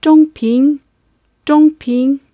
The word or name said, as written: zhongping